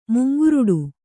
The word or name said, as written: ಮುಂಗುರುಡು